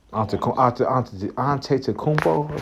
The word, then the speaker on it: Antetokounmpo: They could possibly beat Acuatempo. What's the name? My bad. Giannis Antetokounmpo.